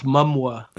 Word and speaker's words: Momoa: I believe it's pronounced Momoa.